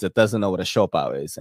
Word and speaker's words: Siopao: Siopao clearly sounds Chinese. It's spelled S-I-O, Sho, and then Pao, P-A-O.